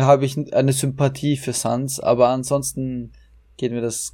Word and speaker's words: Sans: Sans? – Meinst du den, den Genau, ich, ich, konnte das nie aussprechen. Wie heißt der Sans? Sans. Ah ja, genau, äh, Sans, genau, richtig, genau.